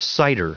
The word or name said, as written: cider